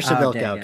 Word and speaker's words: Przybylko: Fire have had a pretty decent office season, adding Kasper Przybylko from Przybylko. Kasper Przybylko. Oh, come on. How dare you mispronounce that?